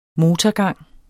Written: motorgang

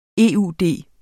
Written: eud

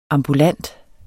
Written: AMBULANTE

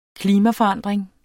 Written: klimaforandring

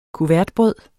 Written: kuvertbrød

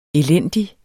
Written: elendig

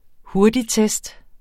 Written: hurtigtest

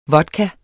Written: vodka

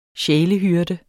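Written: SJÆLEHYRDER

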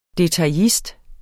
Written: detaillist

